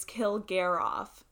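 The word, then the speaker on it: Kilgariff: So, and I actually mispronounced her last name. So, just gonna correct myself. It's Kilgaroff. I think I said Kilgaroff. And I remember in an episode, she was like, yeah, that's what people pronounce and it's not right.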